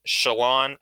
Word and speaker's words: Shallan: Kaladin, Shallan, I think I'm saying that right. – Yes.